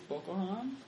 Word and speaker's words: Boko Haram: I think it's Boko Haram.